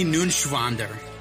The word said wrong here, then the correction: Neuenschwander: You didn't pronounce it right. Anthony Neuenschwander. – It's N-E-U-E-N. It's Neuenschwander. Anthony Neuenschwander.